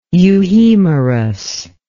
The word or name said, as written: euhemerus